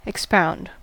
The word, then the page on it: expound: American English